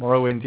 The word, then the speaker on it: Morrowind: Morrowind, yeah.